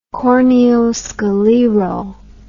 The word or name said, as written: corneoscleral